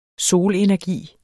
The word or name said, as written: solenergi